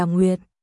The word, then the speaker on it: Nguyệt: chữ đó đọc là Nguyệt.